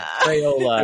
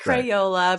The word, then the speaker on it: Crayola: Crayola. Crayola. Yeah. It's like payola, but with a cray. It's Crayola. It's Crayola, right.